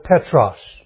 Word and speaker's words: Petros: Because Jesus Himself said to Peter, "Thou art Peter," and He used the word Petros. We would spell it P-E-T-R-O-S, Petros.